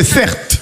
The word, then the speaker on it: Certe: Mon prénom, c'est Serte. Ça s'écrit C-E-R-T-E.